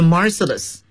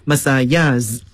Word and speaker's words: Marseillaise: 这是著名的马赛曲的作者大家注意这个马赛曲啊它的英语读法嘛 ,The Marseillaise。当然了我们也可以采用它原始的这个法语的发音 Marseillaise 都可以啊，